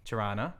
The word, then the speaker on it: Toronto: Toronto. Yeah. You never pronounce the T in the second half.